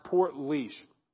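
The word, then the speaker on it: Portlaoise: You probably wouldn't think it was spelled Port Leash, but it's called pronounced Port Leash.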